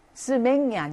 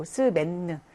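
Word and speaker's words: semaine: 그다음에 한 주, 그 하나의 월화수목금토 일, 그 주를 말하는 단어는 스맨느죠. 스맹이 아니고 스맨느. 요것도 서로 다르게 발음이 나죠.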